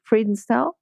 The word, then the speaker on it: Friedenstall: And hopefully we'll see her back in the competition ring soon with her warm blood Friedenstall, which she calls Coffee. Is that how you pronounce it? Friedenstall? – Yeah, Friedenstall is correct. – Yeah, it's actually a German town.